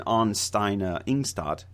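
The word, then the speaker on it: Anne Stine Ingstad: The most famous site is Lons á Meadows, which was uncovered by the husband and wife team of Helga and Ann Steina Ingstad. Did I say that right? – I believe so.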